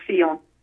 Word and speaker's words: Fillon: François Fillon is my understanding, and my French is pretty lousy, but I believe that is how his last name is pronounced, Fillon.